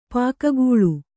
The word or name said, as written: ಪೊಗೆಗೊಳ್